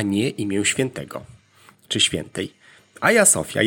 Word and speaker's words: Hagia Sophia: Tak więc najczęściej popełniany błąd to wymawianie Hagia Sophia. Nie jest tak. Prawidłowa wymowa obejmuje nie mecha, więc mówimy Hagia Sophia.